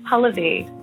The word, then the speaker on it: Pallavi: Pallavi. It actually rhymes with your name.